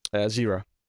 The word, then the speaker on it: Zira: Zira. – Zira. That it may be Zyra, admittedly, but I would pronounce that as Zyra based on the text. – I think you're right. I think it's Zyra.